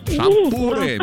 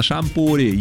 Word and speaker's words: шампур: Итак, запомните, Олечка, и вы особенно, что везде, как бы вы там ни переворачивали, ударение во всех формах существительного падает на букву «у». Шампуры, металлические шампуры, жарить на шампуре, есть с шампура.